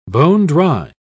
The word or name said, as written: bone-dry